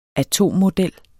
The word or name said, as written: atommodel